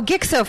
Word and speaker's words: Gixo: It's G-I-X-O fit. – Oh, Gixo fit.